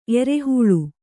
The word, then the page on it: ಎರೆಹೊಲ: kannada